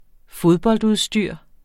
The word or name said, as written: fodboldudstyr